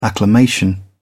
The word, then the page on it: acclamation: British English